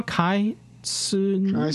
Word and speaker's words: kitsune: So, to whom it may concern, the word Kitsun, Japanese for Kitsun. See, I'm already mispronouncing it. – Yeah. – For Fox is pronounced Kaitsuni.